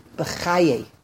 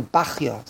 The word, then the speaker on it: Bachya: the Rashi is quoted by Rabbeinu Bechaye. Or Rabbeinu Bachya, some pronounce it different ways, Rabbeinu Bachya, Rabbeinu Bachaye, and he quotes a Rashi,